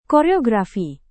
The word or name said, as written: koreografi